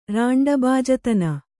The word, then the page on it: ರಾಂಡಬಾಜತನ: Kannada